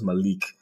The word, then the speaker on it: Malik: And my name is also Arabic, so people who have, like, an Arab background will know it's Malik versus Malik.